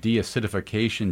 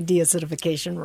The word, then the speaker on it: deacidification: And deacidification, I said it right. Welcome to back to Kansas City. It's nice to have you here. – Thank you. It's really wonderful to be here. And you did say deacidification right.